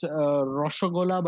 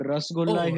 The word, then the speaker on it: Rasgulla: Oh, I have that thing with there's this Indian sweet called the perfect pronunciation is Rasgulla, but it's called Rasgulla here.